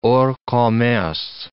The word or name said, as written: hors commerce